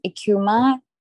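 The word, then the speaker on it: Eucheuma: One is ecuma, that's how you say it. – Yeah. – And I just gave it a Creole pronunciation for my brand, ecuma, because that's how we say it here.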